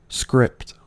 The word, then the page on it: script: American English